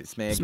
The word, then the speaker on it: Smaug: Smaug. – Smaug.